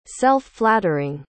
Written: self-flattering

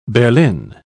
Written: berlin

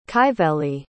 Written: kyveli

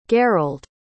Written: garold

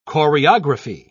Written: choreography

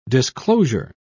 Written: disclosure

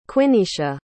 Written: quineisha

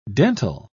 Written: dental

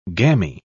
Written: gammy

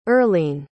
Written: earline

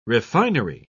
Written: refinery

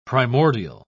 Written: primordial

